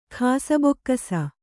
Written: ಖಾಸಬೊಕ್ಕಸ

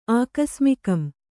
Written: ಆಕಸ್ಮಿಕಂ

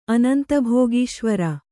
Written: ಅನಂತಭೋಗೀಶ್ವರ

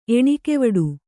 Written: ಎಣಿಕೆವಡು

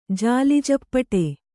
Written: ಜಾಲಿಜಪ್ಪಟೆ

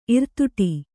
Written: ಇರ್ತಡಿ

